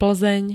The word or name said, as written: Plzeň